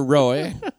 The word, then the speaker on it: Roee: Roy. He spells his name Roy. R-O-E-E. – R-O-E-E.